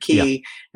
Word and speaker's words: quay: I mean, when he describes what goes on with that population, you know, that is stuck on this quay, I guess you pronounce that word, quay, yeah, at Smyrna, and they're trying to evacuate them, but the enemy forces are right there.